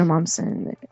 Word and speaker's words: Momsen: It's Taylor Momsen.